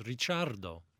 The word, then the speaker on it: Ricciardo: It's Ricciardo.